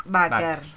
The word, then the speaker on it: Bakker: El apellido se dice así como se lee nomás, Ah, Bakker.